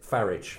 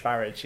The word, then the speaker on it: Farage: He wanted, as he pronounces it, Farage. – Farage, yes, to rhyme with garage.